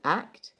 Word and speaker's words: AKT: called ACT. It's spelt A-K-T, and it was developed by West End Performers.